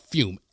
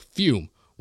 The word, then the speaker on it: Fum: No, I'm talking about fume, F-U-M, but pronounced like fume without the environmental hazards.